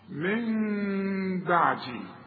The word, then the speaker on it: من بعدي: يبقى هنا في ثلاث احكام العلماء بيقولوا: اقلاب هنقلب النون الى ميم، ونخفيها بالشفايف اخفاء مع الغنه، واسمع نطقها من بعدي، ما اقولش من بعدي واقولها نون، ولا اقولها ميم خالصة واقول من بعدي، يحصل انفراد في الشفايف.